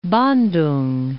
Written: Bandoeng

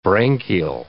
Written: branchial

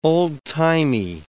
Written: old-timey

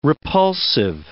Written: repulsive

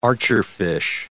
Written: archerfish

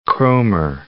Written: Kroemer

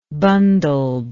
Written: bundled

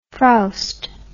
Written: frowst

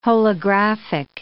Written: holographic

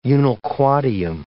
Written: unnilquadium

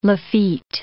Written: Lafitte

